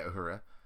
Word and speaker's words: Uhura: And, is that how you say it? – Yeah, Uhura.